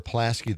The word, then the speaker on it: Pulaski: Is there a Pulaski there? – On the way to Green Bay, and that's how they say it, right? – I say it Pulaski, yeah.